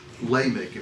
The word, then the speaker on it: Lamech: In fact, it's sort of epitomized by Lamech. At least that's the Jeff pronunciation. Lamech, if you're from Texas.